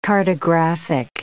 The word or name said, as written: cartographic